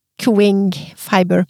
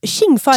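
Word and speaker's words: Tsjing Fiber: Men hun mente at eh, du sa det rett en gang, for du, hun mener altså da at det uttales Ching Ching ah, Fiber. – Ching, ching, fiber. – ching fiber. – Skal det være Ching eller Ching? T-S-J-I-N-G.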